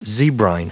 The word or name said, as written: zebrine